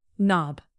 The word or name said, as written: knob